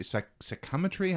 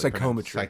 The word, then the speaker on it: psychometry: Now, early on here at the beginning, too, as we have Cal meeting Seer, we also learn that Cal has psychometry. Psychometry? I can't really Psychometry. – Pronounce. Psychometry. Thank you.